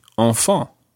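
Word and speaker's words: enfants: And the plural of this, the word for children, it sounds exactly the same, although it's got a silent S on the end. So you would say enfant.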